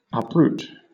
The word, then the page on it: uproot: British English